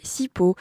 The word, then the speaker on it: Sipho: Oui, si comme moi, vous aimez ces timbres de voix qui hurlent la sensibilité et la puissance, eh bien vous allez adorer Sipo. D'ailleurs, hier, en googlant son nom, parce que ça s'écrit S-I-P-H-O, le premier résultat sur lequel je suis tombée est apparemment un appareil d'étirement qui soulage les mal de dos.